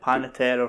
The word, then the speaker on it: Panettiere: Is it like Panettiere or something? – Yeah, aye.